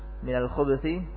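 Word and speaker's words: minal khubuthi (الخبث): Qiraah yang pertama dengan diharakatinya huruf ba dengan dhammah, jadi bacanya seperti ini minal khubuthi, minal khubuthi, alba banya di dhammah, minal khu habis itu banya diharakati dengan harakat dhammah, minal khubuthi.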